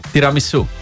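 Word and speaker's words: tiramisù: tiramissu. tiramissu. tiramissu. mitte tiramisu ja mitte tiramisu, vaid tiramissu.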